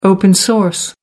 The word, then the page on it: open-source: American English